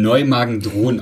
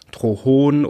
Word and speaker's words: Neumagen-Drohn: Leute sagen, also das Neumagen sagen Leute meistens richtig, aber Thron wird ähm, manchmal mit T oder das H an einer anderen Stelle ausgesprochen, dann ist das so Neumagen. Drohon oder sowas. Das ist ganz lustig, genau. Das klingt so ein bisschen wie aus Star Trek, Drohon.